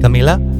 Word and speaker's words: Camila: Camila? – Yeah, it's good.